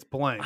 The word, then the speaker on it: Blanc: By the way, is it Mel Blanc or Mel Blanc? I think it's Blanc. – I don't right? – know, actually. – I think they pronounce him Blanc, even though it's spelt like Blanc.